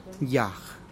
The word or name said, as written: yaj